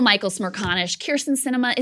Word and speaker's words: Sinema: In it, Dowd writes, the Arizona senator's name is pronounced Sinema,